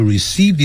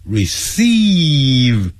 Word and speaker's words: receive: I'm glad to receive your letter. 不要念成 receive 啊，是拉长的音，长元音。